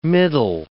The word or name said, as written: middle